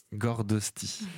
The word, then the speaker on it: Gordosti: On écoute Raga Pop, le titre Gordosti. – Je ne sais pas si je le prononce bien. – Si, très bien.